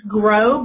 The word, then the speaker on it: Greaux: The last name is, excuse me, it's Grow, but it's spelled G-R-E-A-U-X.